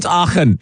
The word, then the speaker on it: Aachen: It's Aachen, right? – We'll call it Aachen.